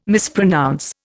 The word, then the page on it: mispronounce: Indian